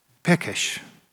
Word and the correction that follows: pesach: ursäkta, pesach, det uttalas 100%, men cirka det.